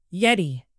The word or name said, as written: yeti